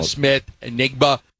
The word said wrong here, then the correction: Smith-Njigba: 50 is a little strong, but I would say the last 20 times you've used Jackson, Smith, and Jigba.